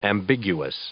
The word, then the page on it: ambiguous: adjective